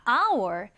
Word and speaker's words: hour: 자, hour 이 단어를 딱 보면 H로 시작하니까 hour이라고 읽어야 할 것 같지만 첫 번째 H는 묵음, 소리 나지 않습니다. 그래서 hour, hour이라고 읽어주시면 돼요.